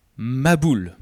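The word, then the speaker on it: mabboul: Ça se prononce « maboul », « déluge ».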